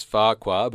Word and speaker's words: Farquhar: Discussion, about whether or not Farquhar, which is spelt with a double F at the beginning, is pronounced like you're stuttering, or just with the one singular noise. – Yeah. – So I'm of the belief that it's Farquhar, but of course, in the TV series, and Connor here, it's pronounced with the two F's.